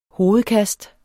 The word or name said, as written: hovedkast